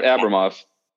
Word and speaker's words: Abramov: Uh, yeah, Ab- Abramov.